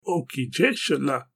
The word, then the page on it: o ki jeshola: Galindri